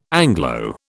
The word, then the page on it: Anglo: British English